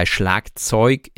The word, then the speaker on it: Schlagzeug: Also Schlagzeug. Das G wird eigentlich nur bei der IG-Endung als CH ausgesprochen. Also richtig, ja, für geschrieben richtig. Und bei Schlagzeug ist es aber eigentlich das G.